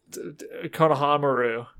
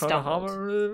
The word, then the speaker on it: Konohamaru: Konohamaru. – Thank you. – Did I say it right? – Kinda. You just Konohamaru? – Stumbled. Yeah, it's fine.